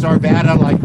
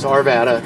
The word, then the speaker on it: Arvada: How old is Arvada? Am I pronouncing it correctly? – It was, yes, it's Arvada, like Nevada, like the state of Nevada. So it's Arvada.